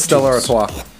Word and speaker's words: Stella Artois: It's Stella Artois.